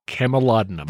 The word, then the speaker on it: Camulodunum: Camulodunum.